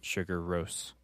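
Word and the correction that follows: Sigur Rós: Sigur Rose.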